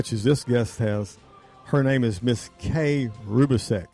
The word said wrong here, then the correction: Rubicek: It's very close. It's actually Rubicek, but you're close. – Rubicek. Okay, I had it right earlier today.